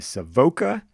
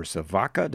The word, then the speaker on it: Savoca: As we mentioned in the tee up, it was directed by Nancy Savoca, or Savaka, depending on how you pronounce it.